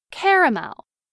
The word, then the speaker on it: caramel: In much of the Northeast and parts of the South, this word is pronounced with 3 syllables. Caramel. In parts of the Appalachian Mountains and much of the Midwest, many Americans pronounce this word with 2 syllables, as in caramel.